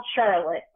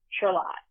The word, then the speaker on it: Charlotte: which is spelled Charlotte, but it's Charlotte.